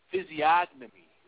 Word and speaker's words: physiognomy: H-Y-S-I-O-G-N-O-N-Y. Again, it's P-H-Y-S-I-O-G-N-O-M-Y. The word is pronounced physiognomy. Physiognomy is the word that's being spelled, and you pronounce it physiognomy.